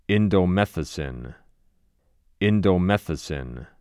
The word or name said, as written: Indomethacin